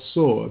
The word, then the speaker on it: Tyre: Go back to the 23rd chapter, it talks about a place called Soar. They spelled it T-Y-R-E. Sounds like Tyre, but it is pronounced Soar, as in Zoar.